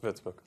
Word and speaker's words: Würzburg: Yes, Würzburg.